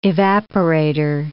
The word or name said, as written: evaporator